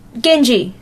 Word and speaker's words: Genji: Is it Gaijin 2? No. – Don't look, dude. – No. Who cares? I said it wrong. Genji. Genji.